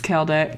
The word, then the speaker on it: Kelda: Hi, my name's Kelda, K-E-L-D-A, like Zelda with a K.